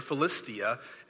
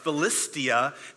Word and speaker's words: Philistia: There was through the region of the Philistines, or Philistia, as she did such a great job pronouncing that. I can never get it right, but Allison did such a great job pronouncing that word, Philistia.